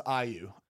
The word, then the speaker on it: IU: Yeah, I think it's pronounced IU. IU. – Like Caillou.